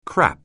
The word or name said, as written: crap